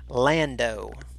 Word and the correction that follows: Lado: No, hold on. I mispronounced that. Sorry. Nathan Lado.